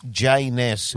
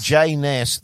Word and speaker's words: Jay Ness: It was Jay Ness. – Jay Ness. – Rhymes with A Ness. – Jay Ness.